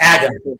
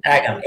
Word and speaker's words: Agam: Just Agam. Agam. A-G-A-M.